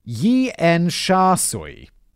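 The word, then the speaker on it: Tsim Sha Tsui: It's spelt like Tsim Tsa Tsui. And it's pronounced Yien Sha Sui.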